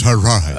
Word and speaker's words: Hadron: Is it Hadron or Hadron? – It's pronounced Hadron.